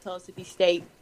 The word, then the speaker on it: Pellissippi: So you go to, I'm going to try to say this right, Pellissippi State? – Yeah, I'll be going back to Pellissippi State this summer.